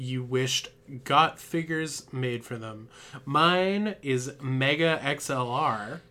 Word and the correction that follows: Megas XLR: Megas XLR. – Oh gotta pro- you gotta pronounce the S.